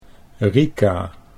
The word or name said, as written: Rika